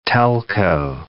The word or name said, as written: telco